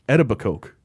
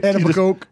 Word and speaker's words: Etobicoke: And so on the documentary, they keep calling it like Etobicoke. – Yeah. – Like, what is... Say it right, stupid. Right? – Etobicoke. – Yeah. Over and over. Etobicoke.